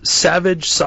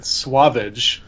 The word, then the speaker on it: Sauvage: Forest Savage. I don't know how you're supposed to say. – Sauvage. They pronounce it Swavage for some reason.